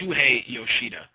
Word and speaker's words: Shuhei Yoshida: says Shusei Yuhita of Sony Computer Entertainment's Worldwide Studios. Actually, I pronounced his name wrong. It's Shuhei Yoshida.